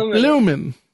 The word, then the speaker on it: Bloomin': But it's spelled B-L-O-O-M-I-N apostrophe, you know, so like blooming, you know, kind of like Australians can't finish words.